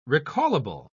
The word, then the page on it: recallable: British English